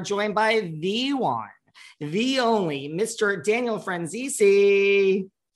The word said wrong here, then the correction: Franzese: Hi, it's Franzese. – Franzese. Okay, well, I mean, I was close, right? – Yeah, pretty close. RuPaul helped me tell people how to say it. It's I don't have my own air conditioner. I have to use my friend's AC.